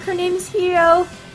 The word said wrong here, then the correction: Hiyori: if I remember how to pronounce that correctly. Let's go look on Wikipedia! Hiyori. See, I gotta hear it in the anime to know how to pronounce it correctly.